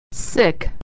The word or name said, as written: sick